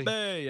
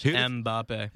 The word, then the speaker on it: Mbappe: Is Mbappe? Am I pronouncing that correctly? – Mbappe, yeah, literally just Mbappe.